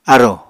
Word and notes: arò: Apatani

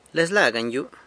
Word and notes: Lezla ganyu: sanlucasquiavini

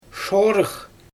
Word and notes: ʃorɯx: Xyzyl

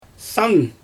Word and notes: san: Xyzyl

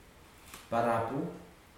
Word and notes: parapu: futuna aniwa

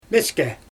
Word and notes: meske: Xyzyl

